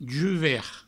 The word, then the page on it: juvert: Provençal